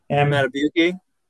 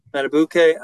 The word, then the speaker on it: Madubuike: Madubuike? – Yeah. – Madubuike.